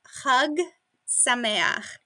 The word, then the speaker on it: Chag Sameach: But uh, it is Chag Sameach. – Chag Sameach. – And that's sound at the beginning at the end is a chet in Hebrew, and it's the same sound at the end of loch in uh, uh, Gaelic.